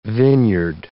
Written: vineyard

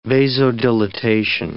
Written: vasodilatation

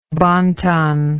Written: bon ton

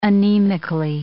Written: anemically

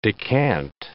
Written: decant

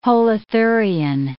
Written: holothurian

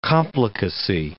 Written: complicacy

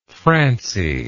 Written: francie

